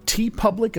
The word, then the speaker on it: TeePublic: You'll see that we have a store on TeePublic. Am I saying that right? – Yes.